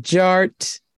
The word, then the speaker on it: DJ Art: And my name is Jared, aka DJ Art, with 2 T's for a double dose of that tink tink. The D is silent, so it's just Jart.